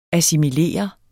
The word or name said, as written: ASSIMILER